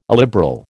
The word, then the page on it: illiberal: American English